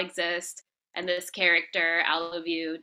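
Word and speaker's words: Alovue: I should clarify that. So it's spelled ALO. Vue, Yeah. – Project Alavu, and it's right play on I love you because it's an app and an algorithm that's capable of love.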